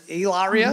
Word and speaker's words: Hilaria: Is it Hilaria? – Yes.